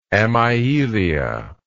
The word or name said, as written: amyelous